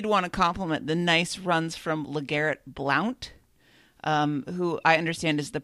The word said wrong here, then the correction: Blount: Actually, it's pronounced Blunt, and he unironically has been suspended at one time for being high.